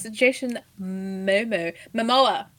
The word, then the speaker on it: Jason Momoa: Yes, Jason Momoa.